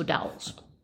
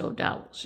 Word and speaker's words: O'Doul's: O'Douls. – Wow, you even know how to pronounce it. – I believe it's O'Douls, yes. – Okay, O-D-O-U-L-S. – That's correct. – Well, you know, I had the O-U-L-S, and I was just like, Ghouls.